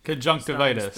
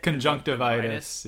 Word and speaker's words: Conjunctivitis: Spells that affect dragons, we talked about this, the Convictus curse, that's the one Crumb used. – Conjunctivitis. Spell. – How do you say it? – Conjunctivitis, yeah.